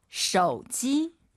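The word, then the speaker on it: 手机: 쇼지. – 오유는 어예요. 쇼지.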